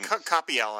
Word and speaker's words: Coppel: The guy's Olivier Coppel? I can't say his name. – Coppel, I thought. – There we go.